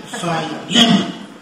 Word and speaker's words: Philemon: In English, I have to remember lemon to pronounce this. Philemon.